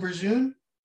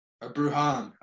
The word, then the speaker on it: Bruján: Vidal Bruján? – Bruján, I think Bruján? It's how you pronounce it. – Sorry, B R U J A N.